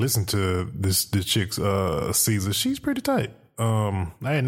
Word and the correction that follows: SZA: I think her name pronounced SZA. – SZA? Okay, my bad. Just like RZA. That's Well, yeah, like the RZA, like she's the SZA.